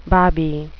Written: bobby

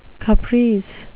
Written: caprice

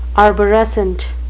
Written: arborescent

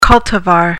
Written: cultivar

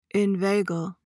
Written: inveigle

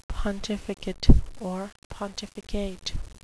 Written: pontificate

